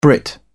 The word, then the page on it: brit: British English